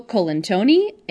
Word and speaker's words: Colantoni: The show starred David Spade, Laura San Giacomo, Enrico Colet... I can never say his name. Enrico Colantoni, a.k.a.